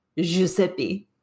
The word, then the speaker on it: Giuseppe: Is that how do you pronounce it? – Giuseppe.